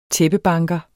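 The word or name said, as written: tæppebanker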